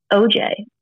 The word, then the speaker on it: Oldziej: Ultimately, my Twitter handle is Kelly OJ, O-H-E. J-A-Y. That is, in fact, how you pronounce my last name, although it is nowhere near spelled how it's pronounced.